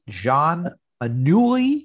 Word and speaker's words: Anouilh: Jean Anouilh. Is that right? Very close. Very close.